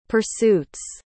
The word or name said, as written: pursuits